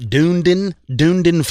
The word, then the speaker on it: Dunedin: Well, apparently there's some other nonsense going on down in Dunedin, Florida. And I hope I pronounced that right. Dunedin, Florida. – Sounds good.